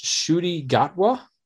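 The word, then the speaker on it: Ncuti Gatwa: Um, I believe it's pronounced Shudi Gatwa. Um, okay. It's spelled N C U T I, and somehow that's Shudi. All right.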